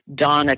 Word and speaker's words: Dana: Her name is Donna Konza. It's spelled Dana, but she pronounces it Donna.